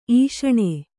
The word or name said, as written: ಈಷಣ